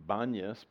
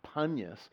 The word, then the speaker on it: Banyas: It gets the name Banyas because the Arabic-speaking people who lived there for so long can't pronounce P, so they say B instead. Banyas, it was Panyas, Pan, after the god, the demonic god, Pan.